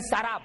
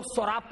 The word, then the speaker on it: سراب: میراج میں سراب, اس کو بعض لوگ سوراب پڑھتے, غلط ہے وہ. قرآن حکیم نے جو تلفظ بیان کیا, جو پروناؤنسیشن, وہی صحیح پروناؤنسیشن ہے. سراب, شراب نہیں, سراب.